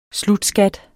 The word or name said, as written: slutskat